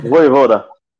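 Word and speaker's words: Vojvoda: O Fortaleza agora com o técnico novo Vojevoda, Vou deixar a pronúncia para quem sabe aí. Voivoda. Isso aí.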